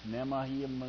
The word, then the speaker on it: Nehemiah: Nehemiah.